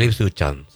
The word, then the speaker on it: Ipswich Town: El, a ver si lo puedo pronunciar bien, Ipswich Town. – El Ipswich Town, sí. – Sí. – Sin leer, muy bien.